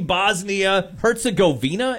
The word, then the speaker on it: Bosnia-Herzegovina: So they were playing Bosnia-Herzegovina, is that yeah.